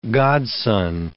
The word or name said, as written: godson